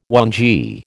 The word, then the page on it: whangee: American English